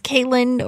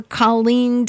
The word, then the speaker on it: Caitlin: So it can either be pronounced Caitlin or Colleen, depending on which sort of source that you look at.